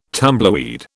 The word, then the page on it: tumbleweed: British English